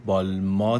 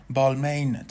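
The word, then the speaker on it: Balmain: ما در زبان انگلیسی خیلی جاها میشنویم که اون رو بالمین تلفظش میکنن و دقیقا همونطوری که نوشته شده میخوننش، ولی در زبان فرانسه فونتیک های آخرین کلمه عضوی از آواهای نزلیزه یا تو دماغی هستن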